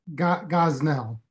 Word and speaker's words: Gosnell: Is it Gosnell or Jocelyn? Yes. – Gosnell.